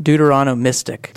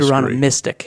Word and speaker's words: Deuteronomistic: I said that. Didn't I say that? – Deuteronomistic. – The Deuteronomistic history. – Deuteronomistic history. You put the emphasis in a different place.